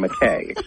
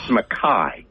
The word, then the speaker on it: McKay: What is M-A-C-A-O-I-D-H? What is that? – Well, that's the Gaelic spelling of my last name, McKay. – Thank you. – The way the Scottish pronounce it, it's McKay.